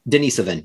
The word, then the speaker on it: Denisovan: Wait, but we do have Denisovan. How do you pronounce that? – Denisovan, yes.